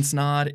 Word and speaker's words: Snod: My name is Ryan Snod. It rhymes with odd, and you're watching and listening to the Rhymes with Odd podcast.